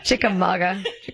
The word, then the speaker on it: Chickamauga: and also in Chickamauga, Georgia. Did I say that right? – Chickamauga. – Yeah. Chickamauga. – Yeah. It's a Cherokee word.